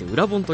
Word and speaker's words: 社長の教科書: ね、社長の教科書と書いて裏本と読ませるって、何か意図があるんでしょうかね。